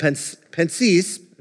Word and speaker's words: Pensées: It's this thing called Pensées, that's how you pronounce it, French guy.